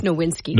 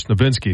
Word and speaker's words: Nowinski: And now there is a, you know, concussion expert, co-founder of the Boston University CTE Center, which is, Chris Nowinski, as I think how you say it, is Nowinski? – I think it's Nowinski. – Nowinski, okay. The jury is out on whether the guardian cap is helping NFL players.